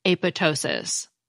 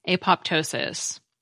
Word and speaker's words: apoptosis: and we usually pronounced the P, apoptosis. Finally, the Oxford English Dictionary shows that in 1994, the esteemed journal Nature sided with the apoptosis pronunciation, calling the silent P, quote, neither correct nor attractive, unquote. Because if I'm reading this right, when the Greek PT combination appears in the middle of a word, the P usually is pronounced.